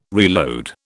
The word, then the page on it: reload: British English